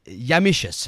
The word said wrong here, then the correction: Yumptious: How do you say it? – It's yumptious.